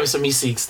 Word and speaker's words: Meeseeks: Miss Meeseeks. Is that how you say it? – Yeah, Mr. Meeseeks.